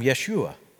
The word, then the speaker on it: Yeshua: Jesus and I, we would have had the same pronunciation of our name, Yeshua.